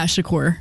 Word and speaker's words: Shakur: So, did I say that right? Shakur? – Shakur.